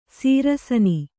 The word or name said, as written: ಶರಾಸನ